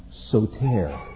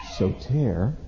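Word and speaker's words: soter (σωτήρ): And this would be pronounced soter, soter, and it means Savior.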